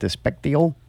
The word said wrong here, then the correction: Despacito: Despacito. – Despacito.